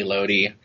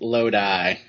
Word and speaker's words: Lodi: But no, Lodi. It's pronounced Lodi. – Lodi.